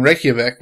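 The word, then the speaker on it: Reykjavík: But they just named a street in a neighborhood in Reykjavik, which is the capital of Iceland.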